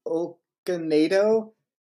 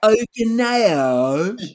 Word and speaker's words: Okonedo: I got to go with Sophie Okonedo. Hopefully, I'm saying it right. It's British. – Hopefully. – Yeah. – Okonedo. – Yeah. That's probably closer to it.